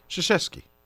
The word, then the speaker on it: Krzyzewski: Krzyzewski.